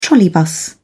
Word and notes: trolleybus: British English

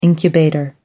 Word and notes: incubator: American English

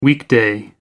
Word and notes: weekday: American English

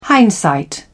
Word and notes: hindsight: British English

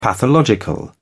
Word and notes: pathological: British English

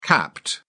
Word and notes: capped: British English

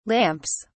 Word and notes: lamps: British English

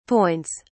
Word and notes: points: British English

